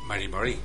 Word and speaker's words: Mari Mari: Mari Mari. – Mari Mari.